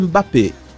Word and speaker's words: Mbappé: No francês é Mbappé. – Mbappé. – O M não se pronuncia.